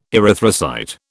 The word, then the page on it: erythrocyte: British English